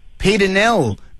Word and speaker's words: Pieternell: Peter Nell. Yes. Now, there is a unique name, Peter Nell. How do you spell it? – P-I-E-T-E-R-N-E-L-L.